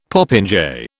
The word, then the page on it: popinjay: British English